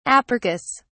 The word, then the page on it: apercus: British English